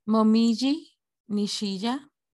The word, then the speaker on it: Momiji Nishiya: Y en la competencia femenina y masculina hubo tres niñas que conquistaron el medallero y fueron eh, híjole, voy a pronunciar los Mojimi Nishiya. – Yo lo puedo decir. Sí, ay, perfecto, por favor. Momiji Nishiya y Raiza Leal.